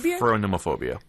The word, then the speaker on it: phronemophobia: P-H-R-O-N-E-M-O-P-H-O-B-I-A. – Phronemophobia. – I hope I'm pronouncing that correctly. I think I am. – Phronemophobia. – I looked at the... – Phronemophobia. Okay. – At the phonetics, and I think I'm saying it correctly.